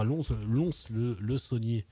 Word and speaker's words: Lons-le-Saunier: Et donc voilà, ils continuent aussi de pas mal tourner puisqu'ils sont à Lons-le-Saunier, on ne prononce pas le S, ce soir.